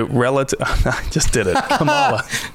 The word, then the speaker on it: Camala: So this planet is named Kamala. – Kamala. – Not Kamala. – Kamala. – That is the vice president. – Ah. Okay. – This one is Kamala, and it is spelled C-A-M-A-L-A.